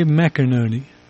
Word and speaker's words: McInerney: They write that Jay McInerney, did I get that anywhere close pronunciation-wise? – That's good.